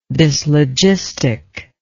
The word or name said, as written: dyslogistically